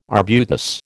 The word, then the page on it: arbutus: American English